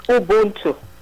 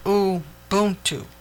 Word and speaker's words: Ubuntu: And you had stated that you wanted to talk about Ubuntu. Is that, am I saying that correctly? – It's Ubuntu. – Ubuntu.